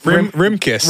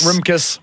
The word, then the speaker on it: Rimkus: And the 4 people were Egbert Rimkus. – Heyo. Yeah. – Sorry, fame. Egbert Rimkus? – Rimkus. – Rimkus. – R-I-M-K-U-S.